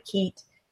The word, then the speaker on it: Keitt: And then, you know, and those are actually, it's the same, it's called a kit. Some people call it keet. It's spelled K-E-I-T-T.